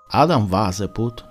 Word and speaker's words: Adam Weishaupt: Per correttezza proverò a pronunciarlo in tedesco. Adam Waseput nasce a Igostat, una città della Baviera, il 6 febbraio 1748.